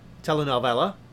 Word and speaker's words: telenovela: From Basic to Telenova. Telenovela. Sorry, I pronounced that wrong because I don't really speak Spanish, but that was the whole episode of that one.